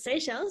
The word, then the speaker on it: Seychelles: Is it Seychelles? Is that how you say it? – Yeah. – yeah.